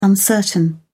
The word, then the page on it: uncertain: British English